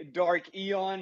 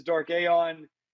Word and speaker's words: Dark Aeon: I say Dark Eon. He says Dark Aeon.